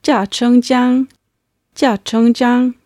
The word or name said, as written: jiachengjiang